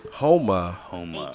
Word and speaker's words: Houma: So you tried to explain to me where is that, but how do you say it again? – Houma. – Houma. – H-O-U-M-A. – H-O-U-M-A. – Yes, there is a Homer, Louisiana, okay, but it's not that.